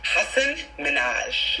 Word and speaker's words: Minhaj: So, what was the experience being a warm-up comic for Hasan, I'm sorry, how do you pronounce his last name? – Hasan Minhaj. – Minhaj is, what was the experience like being a warm-up comic for Hasan Minhaj's Patriot Act on Netflix?